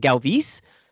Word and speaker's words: Galvis: Speaking of, I don't know if this is Buck being Buck, but on today's broadcast, tonight's broadcast, rather, he's pronouncing it Galvis. So yeah. So I guess I've been wrong this whole time.